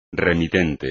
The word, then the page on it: remitente: espagnol